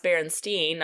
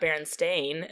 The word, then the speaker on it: Berenstain: It's pronounced Berenstain, not Berenstain. And so it's the Berenstain Birds.